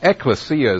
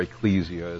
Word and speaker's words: ekklesia: And the term, I guess we should explain, as we did on the telecast, that ekklesia is the Greek word, or ecclesia, as some people like to pronounce it.